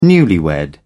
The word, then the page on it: newlywed: British English